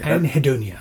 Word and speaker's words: Anhedonia: Was Anhedonia. – I never say it properly. – Anhedonia. – That's how you say it.